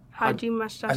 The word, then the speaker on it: hajimemashita: What, one more time, what was it? – Hajimemashita. It's hard to say it slower. Hajimemashita. – Hajimemashita. – I think, I'm probably even saying that wrong. – Hajimemashita. – Yes. – Yeah. – Really? – That's how you say, nice to meet you.